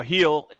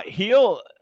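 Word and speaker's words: Gil: You know, Heel is the way they pronounce it. Heel.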